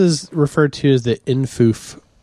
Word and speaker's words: infoof: Well, they whimsically insist that it be pronounced infoof.